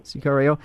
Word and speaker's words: Sicario: There's also a documentary, El Sicario, Room 164. Am I pronouncing that correctly? – Mm-hmm. – Sicario.